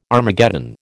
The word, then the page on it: Armageddon: American English